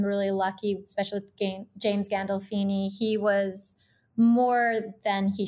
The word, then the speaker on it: Gandolfini: I think about Emilio Estevez on the Mighty Ducks now, and of course, you working with James Gandolfino and have I said it right? Gandolfini? – Gandolfini. Yeah, you almost Gandolfini. Had. I knew it was wrong as soon as I said it.